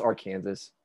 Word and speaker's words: Arkansas: So, yeah, it's pronounced Arkansas.